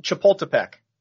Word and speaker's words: Chapultepec: And to kick it all off, this is the first WGC of the year in Mexico City, Club de Golf Chapultepec.